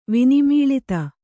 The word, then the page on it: ವಿನಿಮೀಳಿತ: kannada